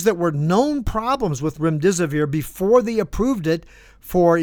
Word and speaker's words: remdesivir: It's almost certain that one of the popular treatments used by the medical establishment back then, remdesivir is how you say it, contributed to the deaths of many of these, many thousands of them.